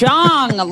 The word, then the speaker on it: Jong: It's Jong, like Kim Jong. – Really? It's not a soft J.